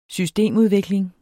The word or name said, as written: systemudvikling